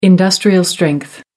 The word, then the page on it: industrial-strength: American English